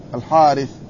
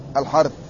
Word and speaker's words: الحارث: قال ولا يلتفت إلى زيادته، ولا تعل بها رواية الثقة عمرو بن الحارث ومعه عميرة ابن أبي ناجية الحارث، هكذا تكتب لكن تنطق الحارث، لأنه لا يعرف في الأسماء الحرث.